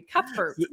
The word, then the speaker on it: Cuthbert: Cuthbert, awesome job.